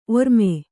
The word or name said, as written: ಒರ್ಮೆ